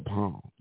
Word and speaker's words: Psalm: Oh, That's for those that don't know that the P is silent. – The palm.